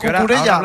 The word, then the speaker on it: Cucurella: Lo pronuncias a tu, sí, a tu, sí, aire, eso, lo de Cucurella. – Por ir, por ir rápido. – ¿Qué? Cucurella, perdón, que no se enfade nadie, hombre.